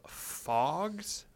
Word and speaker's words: Phogs: I tried out a game called Fogs, I believe is how you say it. It's spelled Phogs. – Okay. – Yeah. Yeah, PH fog.